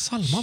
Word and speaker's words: Psalma: Med Psalma va? Med PS? – Ja, ja, men det uttalas väl inte riktigt så.